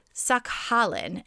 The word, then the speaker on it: Sakhalin: Sakhalin, S-A-K-H-A-L-I-N,